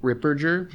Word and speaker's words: Ripperger: I would also like to recommend a video by Father Chad Ripperger, Ripperger on hell. And actually, sure that's how you pronounce his name. – Soft I mean, G. That's how he pronounces it.